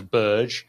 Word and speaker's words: Berge: none of us ever agreed how to say his surname, which, you know, you heard him say it himself several different times in different ways. When he joined Burnley, he said it was Burge.